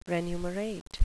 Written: renumerate

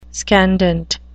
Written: scandent

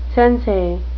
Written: sensei

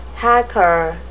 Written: hacker

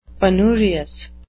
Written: penurious

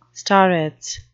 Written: starets

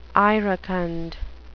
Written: iracund

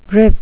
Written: grip

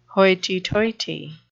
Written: hoity-toity

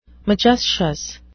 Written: majestious

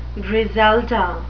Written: griselda

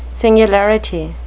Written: singularity

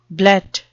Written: blet

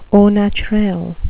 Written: au naturel

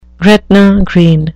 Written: Gretna Green

